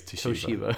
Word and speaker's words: Toshiba: Is that how you say it? – No, I'm just being an asshole. – Jesus. I was like, whoa, have I been saying it wrong the whole time? – No, I think it's Toshiba.